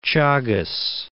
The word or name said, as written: Chagos Archipelago